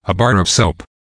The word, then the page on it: a bar of soap: American English